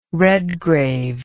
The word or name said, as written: Redgrave